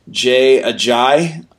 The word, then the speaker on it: Ajayi: Now, I've heard his name on television several times, and I think we've been saying it wrong all along. – I don't care. – I was not going to change it. as he was called in CBS, Jay Ajayi.